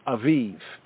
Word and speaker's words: Abib: Abib is even directly mentioned in the Torah, A-B-I-B. In modern Hebrew, it is referred to as, or pronounced as, Aviv, right? Aviv, from the, we could say, the Eastern, you know, the Eastern European mother tongue, they will say Aviv.